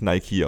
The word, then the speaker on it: Nike: Weil man versteht, das ist so, wie man weiß, dass Nike eigentlich Nike ausgesprochen wird und das auch so durchzieht in seiner Umgebung.